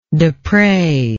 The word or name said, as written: despres